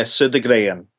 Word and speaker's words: Sodergren: No, it would be a Sodergren, which is Swedish, but I will answer to anything.